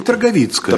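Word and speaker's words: Тарговицкая: Конечно, вот на эту торговецкую конфедерацию, прошу прощения, если я делаю неправильное ударение здесь. – Ну, по-польски торговицкая.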